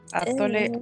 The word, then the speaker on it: atole: Atole. I don't know. See, atole, pronounced atole, is a traditional beverage in Mexican or Latin cuisine made from masarina, the type of corn flour.